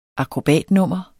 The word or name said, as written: akrobatnummer